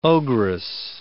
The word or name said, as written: ogress